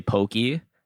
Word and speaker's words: poke: The Hawaiian values are pronounced how you pronounce the Spanish values. A, A, E, O, U. So you would say poke, not poke E.